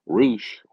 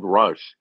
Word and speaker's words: Rush: And finally, number three, Roosh, or as I like to call him, because it's the way it's spelled, Rush, taking on Christopher Daniels.